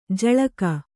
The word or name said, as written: ಜಳಕ